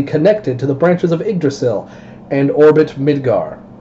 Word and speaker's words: Yggdrasil: You got Yggdrasil from a Y and two G's next to each other? – That's how you say it.